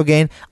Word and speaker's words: Gane: I think it's Gane, right? Cyril Gane. – Yeah, it is Gane. – Yeah, Gane. Right. So Junior Dos Santos versus Cyril Gane.